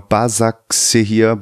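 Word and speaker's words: Başakşehir: Ich weiß nicht, ob ich es richtig ausspreche. Bekashir Istanbul oder so, der Erdogan-Verein. – Genau, Basaksehir, Basaksehir.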